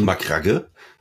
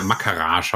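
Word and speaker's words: Macragge: Aber allen Anschein nach heißt die Heimatwelt der Ultramarines McCrack. Ja, das ist Nicht Makragge? – Makragge. Ja, Makarage habe ich auch schon gehört.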